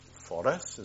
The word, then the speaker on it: Forres: Now, if you're ever in Forres, which is the correct pronunciation, Forres, as they say, if you're ever in Forres, the house next to the bowling green is the house we live in.